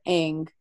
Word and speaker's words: Aang: So it's cool to see the Avatar, Aang.